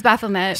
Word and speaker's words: Baphomet: Is it Baphomet or Baphomet? It's Baphomet. – Satan was not pretentious. He would not have had a silent T. There's one thing I know about Satan. He wasn't French.